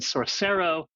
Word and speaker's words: Sorcero: And you said Sorcero. Think of source code and sorcery.